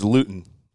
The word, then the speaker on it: Luton: Am I saying his name right? Jake Luton. – That's Luton.